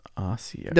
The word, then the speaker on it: Ossiarch: Yeah, I think it's Osiarch. – Osiarch, that sounds cooler. Osiarch Bone Reapers. – Or is it Osiarch? – Ossiarch.